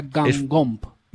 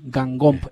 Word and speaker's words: Guingamp: él estuvo en un equipo que se escribe Guingamp, pero creo que se pronuncia Gangomp. – ¿En Francia? – Sí, Gangomp, estuvo en ese equipo.